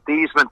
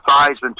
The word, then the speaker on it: Theismann: We think just by changing the pronunciation of your last name from Theismann to Theismann to rhyme with Heisman, we can get you that trophy."